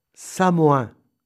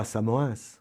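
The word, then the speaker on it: Samoëns: Samoin, la station de ski, et non pas Samoins.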